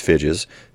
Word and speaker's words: Figes: I think it's pronounced Figes. That's spelled F-I-G-E-S, so I believe that's how it goes.